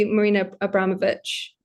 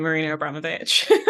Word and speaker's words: Marina Abramović: I say Marina Abramović. – Exactly the same, Marina Abramović.